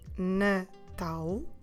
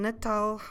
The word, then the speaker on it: Natal: Natal. And Natal, como se escreve Natal? N-A-T-A-L.